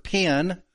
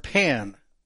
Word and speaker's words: pen: You can hear the difference in the vowels easier if you pronounce pig versus peg. Peg. You know, as in Peg McCamey. It's the same difference, vowel sounds. P-E-N is pen. P-I-N is pen.